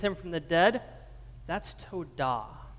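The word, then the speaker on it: todah (תודה): Let me hear you all say todah.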